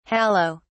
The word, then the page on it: hallow: British English